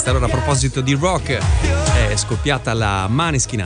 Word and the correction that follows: Måneskin: anzi Måneskin, come oramai abbiamo capito bisogna pronunciarli, la Måneskin mania.